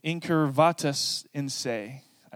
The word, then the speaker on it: incurvatus in se: It's this concept of incurvatus in se.